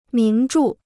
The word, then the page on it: 名著: míng zhù